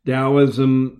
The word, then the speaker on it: Taoism: Taoism sounds like it's spelled with a D, but actually usually it is spelled with a T. T-A-O-I-S-M,